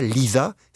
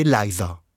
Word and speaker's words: Liza: C'est pas Liza, c'est Liza.